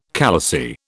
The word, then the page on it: khalasi: British English